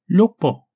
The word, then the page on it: Lo-poh: Galindri